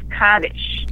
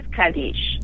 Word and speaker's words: Kaddish: So in that strain of Hebrew, it was Kaddish. – Okay. – Now, the Israelis speak Sephardic Hebrew. And in that case, it is Kaddish. – Right. – So when someone says, how do I pronounce it, Kaddish or Kaddish, I say yes.